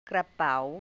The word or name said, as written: กระเป๋า